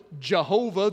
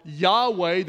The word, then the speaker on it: Yahweh: Jehovah, the old pronunciation, or Yahweh, the new one.